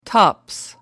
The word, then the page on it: tops: American English